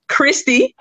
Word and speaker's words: Christie: Christy, C-H-R-I-S-T-I-E, Black, like the color, Morrell, M-U-R-R-E-L-L.